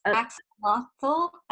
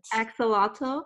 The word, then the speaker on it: axolotl: Axolotl, I believe is how you pronounce it. – Axolotl. Yes. It's so adorable.